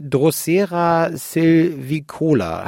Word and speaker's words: Drosera silvicola: Diese fleischfressende Pflanze, die ihr jetzt entdeckt habt, die Drosera silvicola, habe ich das richtig ausgesprochen? – Ja, das ist sogar richtig ausgesprochen.